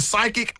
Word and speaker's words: psychic: I know you're so you're right, because when we see psychic, it's spelled P-S-Y, right? – I couldn't tell you. – Yes, yeah, yep. – So the P is silent.